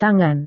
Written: tangan

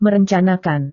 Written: merencanakan

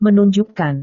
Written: menunjukkan

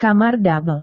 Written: kamar double

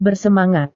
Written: bersemangat

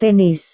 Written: tenis

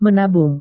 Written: menabung

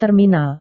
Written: terminal

